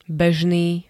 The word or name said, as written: bežný